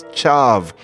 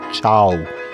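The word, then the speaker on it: ciav: And I don't feel bad because nobody knows Piedmontese outside of the area, but I said that the word for key was chav. But no, that V is pronounced as a W. I should have said chow.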